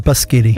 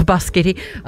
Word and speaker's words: biscotti: It's pronounced biscotti. – Oh, biscotti.